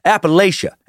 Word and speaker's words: Appalachia: All supposed encounters with them and folklore about them has come from Appalachia. And yes, that is how I'm going to pronounce it today for the most part.